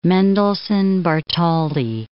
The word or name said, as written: Mendelssohn-Bartholdy